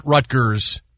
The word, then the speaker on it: Rutgers: And just so everybody know, it's Rutgers. It's not Rutgers. It's Rutgers. That's how you say it. – Okay? But it's the State Rutgers. College of New Jersey. – It's Rutgers. That's how you say it. It's always been said Rutgers. There's no, you know, you have to say R's when you have to, but you don't say all the R's, right?